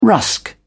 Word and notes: rusk: British English

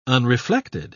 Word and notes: unreflected: British English

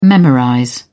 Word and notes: memorize: British English

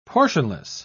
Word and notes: portionless: British English

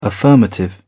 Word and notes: affirmative: British English

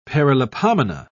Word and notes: paralipomena: British English

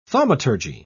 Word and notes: thaumaturgy: British English

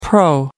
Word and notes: pro: American English

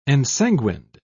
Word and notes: ensanguined: British English

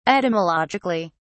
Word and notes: etymologically: British English

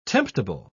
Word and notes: temptable: British English

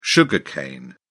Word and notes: sugar cane: British English

